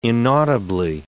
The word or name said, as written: inaudibly